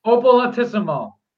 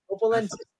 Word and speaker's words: Opolentisim: It's Opolentisim. It's phonetic. It's just like it's spelled. – Opolentisim. – Opolentisim. There you go. – Opolentisim. Thank you, Dennis. Opolentisim.